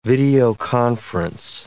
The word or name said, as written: videoconference